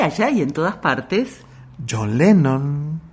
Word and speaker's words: Sean: Por supuesto, porque es el saludo de John Lennon, pero no del John de J. O-H-N, sino del hijo de Sean. Se escribe Sean y se pronuncia John.